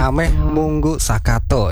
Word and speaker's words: Ameh Munggu Sakato: Ameh Munggu Sakato ya. Begitu ya, benar ya untuk pengucapannya ya.